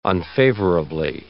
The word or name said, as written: unfavorably